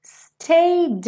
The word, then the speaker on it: stayed: Stayed in bed. E aí lembra que você não fala stayed, esse E não é pronunciado. E aí você já junta o D direto no in bed. Stayed in bed all morning just to pass the time.